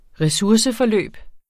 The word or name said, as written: resurseforløb